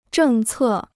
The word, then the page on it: 政策: zhèng cè